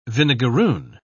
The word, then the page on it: vinegarroon: British English